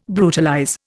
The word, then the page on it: brutalize: Indian